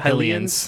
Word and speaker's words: Hylians: Is that how you pronounce the Hylians? – Hylians. Like helicopter.